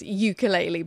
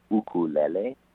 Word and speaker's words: ukulele: I mean, obviously, a Western way of saying it more is ukulele, but I know it's originally a Hawaiian word meaning a jumping flea, I think, and obviously the way you're pronouncing it's the correct way. – Well, I was born and raised in Hawaii, so, the way that I was taught to say it is ukulele.